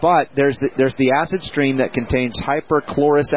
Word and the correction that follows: hypochlorous: acid. Is that how you say it? – Hypochlorous. – Hypochlorous acid. Okay.